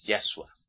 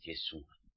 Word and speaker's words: Yeshua: Miren, el judío ortodoxo te dirá que no es Yahshua, te dirá que es Yeshua.